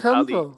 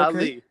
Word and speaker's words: Ali: And so I was like, they can't pronounce my name, even though my name was simple, Ali, just three letters. Two syllables, okay. Ali.